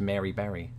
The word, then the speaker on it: Mary Berry: By the way, how do you pronounce Mary Berry? – Mary Berry. – It rhymes, so, but not when I say it.